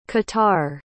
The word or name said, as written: qatar